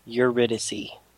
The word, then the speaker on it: Eurydice: And then we'll be doing the first two of a three-part arc called Eurydice. – Eurydice.